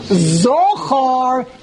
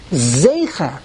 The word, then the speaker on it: zecher: If you want to take this approach to make sense of the mistake, the real way to pronounce the word is zecher. The Rebbe said in komatz katan. Komatz katan is two dots.